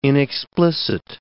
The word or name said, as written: inexplicit